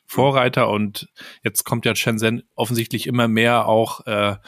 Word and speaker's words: Shenzhen: Shenzhen, ja. – Shenzhen, ja.